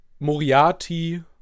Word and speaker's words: Moriarty: Ähm, ich bringe mit Moriarty.